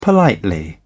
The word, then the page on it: politely: British English